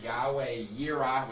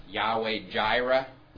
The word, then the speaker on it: Yahweh Yireh: And there's Yahweh Yireh, which many of you have heard pronounced Yahweh Jireh. The J there comes from the same error of pronouncing German in English that J comes from in Jehovah.